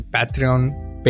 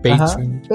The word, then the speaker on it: Patreon: o no sé cómo se dice Patreon, Patreon.